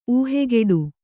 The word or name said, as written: ಊಹೆಗೆಡು